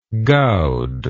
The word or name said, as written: gowd